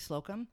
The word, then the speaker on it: Slocum: It's actually Slocum. I'm going to tell you why I knew how to pronounce Slocum. My parents' little Amish village they live in has a market called Slocum's. And it's open from like 8 to 8.